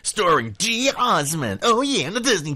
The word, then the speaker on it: geosmin: Some people do pronounce it geosummon as well, or geosmin. And it's basically spelled G-E-O-S-M-I-N.